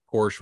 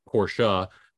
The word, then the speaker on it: Porsche: Probably they want to, you know, sneak in and do the same thing that Porsche was trying to do with, or sorry, Porsche, because I will get a phone call from Dylan that I didn't pronounce that right, because he is, remember, the number one Porsche dick rider.